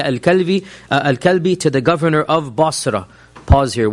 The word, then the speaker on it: Basra: Any reference of Basra, which is how it's pronounced, in the Sira literature is to a small town south of Damascus.